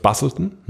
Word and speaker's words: Busselton: Es ist ja schon wirklich nur ein Side-Note, aber es war der 70-3, wie spricht man es, Busselton, ne? – Ja, ja.